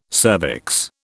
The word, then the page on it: cervix: British English